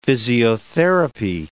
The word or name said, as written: physiotherapy